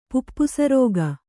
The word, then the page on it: ಪುಪ್ಪುಸರೋಗ: puppusa rōga